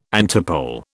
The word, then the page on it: antipole: British English